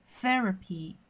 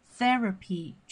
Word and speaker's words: therapy: Alright, let's check out 我们今天的一个词汇中比较重要的词叫做 therapy。 therapy，注意一下这个 th 的发音哈，是跟我们说的谢谢这个词 thank thank you 是一样的。